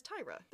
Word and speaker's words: Tyria: So Tyra's name is spelled T-Y-R-I-A, which looks like Tyria. – Tyria or Tyria. Yeah. – Yeah. That's not how you say it, because she refers to herself as Tyra.